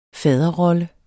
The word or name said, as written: faderrolle